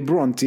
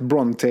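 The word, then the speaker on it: Brontë: Och hade Emily Bronte jag har lärt mig att uttala så. Jag har sagt Bronte hela livet.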